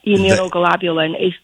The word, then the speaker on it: immunoglobulin: Yep, immunoglobulin.